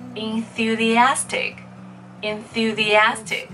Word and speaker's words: enthusiastic: 叫做 enthusiastic，enthusiastic。那么注意一下这个词的拼写里面哈，这个 th 的发音跟 thank you 是一样的，OK？enthusiastic，en。